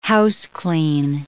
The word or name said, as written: houseclean